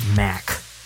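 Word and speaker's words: Macht: I think his last name is pronounced Mack, but it has a T at the end.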